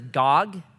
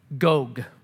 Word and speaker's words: Gog: A lot of people pronounce this Gog. It's actually Gog. It, it rhymes with Vogue, so it's Gog.